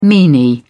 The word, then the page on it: meanie: British English